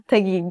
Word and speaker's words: Taguig: And how do you pronounce it again? Yes. Taguig? – Yes. Oh, you did pretty well. Yeah. Taguig.